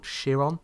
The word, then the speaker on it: Chiron: It was in Percy Jackson and the Olympians, The Lightning Thief, and he played a centaur called Chiron. Is that how you pronounce it? – Yep.